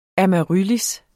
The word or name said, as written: amaryllis